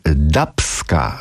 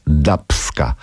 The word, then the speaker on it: Dubska: No a wydaje mi się bardziej prawidłowa jednak dubska. Dlaczego? Otóż chodzi o gatunki, prawda? Mamy dub, czyli muzyka brzmienia dubowe, mamy ska, więc dubska. Tak się, myślę, powinno wymawiać nazwę zespołu.